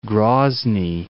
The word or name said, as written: Groznyy